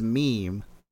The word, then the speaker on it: meme: According to the Oxford English Dictionary and many other sources, the correct pronunciation for meme is meme. Rhymes with team.